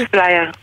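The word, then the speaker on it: AppsFlyer: אנחנו מקדמים בברכה את סמנכ"לית אחריות חברתית בחברת אפספלייר, ככה מבטאים את זה? – אפספלייר, כן, שלום לך.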